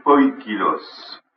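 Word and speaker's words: poikilos (ποικίλος): C'est le mot poikilos, P-O-I-K-I-L-O-S, avec l'accent sur le I, poikilos, qui veut dire barioler, tacheté, moucheté, comme une peau de bête.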